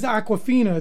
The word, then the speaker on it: Awkwafina: That we'll end the show with, her name is Awkwafina. It's spelled AWKWAFINA.